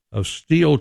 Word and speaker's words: Stihl: Dr. Nicholas Steele, here comes our buddy, is the boss of Steele Chainsaw. It's spelled S-T-I-H-L. – Okay. – Pronounced Steele.